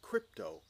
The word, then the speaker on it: krypto: It's written, we would pronounce it crypto, K-R-Y-P-T-O.